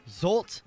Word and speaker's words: Zsolt: Ooh, how do you pronounce this name? Oh. Z-S-O-L-T. – Zolt? – Zolt? – Yeah. – Recently arrested Zolt for attempting to steal a car.